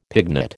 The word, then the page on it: pignut: American English